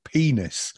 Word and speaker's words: Peaness: And then, yeah, there was this other band that was really loved called Penis. But it's spelt P-E-A-N-E-S-S. And they were really good.